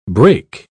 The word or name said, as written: break